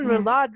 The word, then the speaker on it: roulade: And the chicken roulade recipe, there's something, and am I pronouncing it correctly even? – Yes. Yeah.